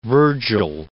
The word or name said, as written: Vergil